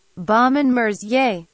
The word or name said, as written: Baume and Mercier